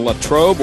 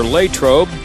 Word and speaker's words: Latrobe: Arnold Palmer Airport in Latrobe, or Latrobe, depending on how you want to pronounce it.